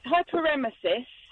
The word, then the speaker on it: hyperemesis gravidarum: Have I pronounced this correctly? Hyperemesis gravidarum, and if I have, how bad is it? Morning to you. – It's hyperemesis. Overdoing of the emesis, vomiting. – Right. – And gravidarum is the pregnancy Brave.